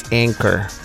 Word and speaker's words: Anchor: Y es parte de, como te digo, es parte de cómo yo hago el podcast, pero obviamente sí tiene su, manera de pronunciación y es Anchor.